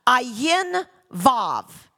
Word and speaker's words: Ayin Vav: You would pronounce the 7-6 like this in Hebrew. Ayin Vav.